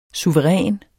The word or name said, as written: suveræn